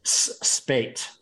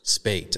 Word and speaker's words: Spaight: Oh wait, how do you pronounce your last name? Spate. Spate. Okay, I was going to butcher that, so I'm glad I asked yeah.